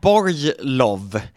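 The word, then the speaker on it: Borglowe: Det är Melina Borglov. Sa jag rätt nu? – Ja, Borglov. Så ska det uttalas.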